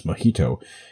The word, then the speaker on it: mojito: Now, mojito, if you're English or just American or Canadian, you would pronounce mojito as mojito because the J is an H.